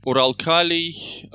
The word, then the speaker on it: Uralkali: Do you want the Russian version? – I don't mind. – Uralkali, Uralkim, I think.